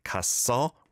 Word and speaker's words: 갔어: But you need to pronounce it 갔어 rather than 가서.